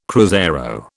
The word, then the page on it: cruzeiro: British English